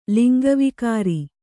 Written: ಲಿಂಗವಿಕಾರಿ

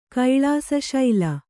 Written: ಕೈಳಾಸಶೈಲ

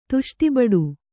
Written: ತುಷ್ಟಿಬಡು

